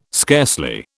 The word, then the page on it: scarcely: British English